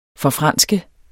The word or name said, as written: forfranske